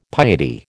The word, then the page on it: piety: American English